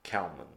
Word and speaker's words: Kalman: My email is Ian Kalman.